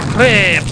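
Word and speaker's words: crêpe: No, I don't know about doudou there, Frenchie, but I thought I smelled some crap Suzette when I jumped in the ditch. – No, monsieur, it is pronounced crepe.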